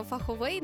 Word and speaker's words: фаховий: Як ти скажеш? Фаховий колега чи фаховий колега? Забудь про фаховий, насправді це єдина правильна норма словника, тільки фаховий з наголосом на букву А.